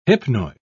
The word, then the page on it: hypnoid: British English